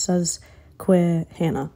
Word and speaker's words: Susquehanna: but it's Susquehanna Road. I don't know if I pronounced that. Why do I feel like I butchered that word, bro? – Man, I butchered that word so hard. It's pronounced Susquehanna.